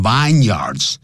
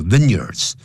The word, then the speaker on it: vineyards: It is thought 一般认为 that the vineyards of the Cape 不要念成 vineyards 叫 vineyards，他说这个开普省的这些葡萄园呢 are the best 是最棒的。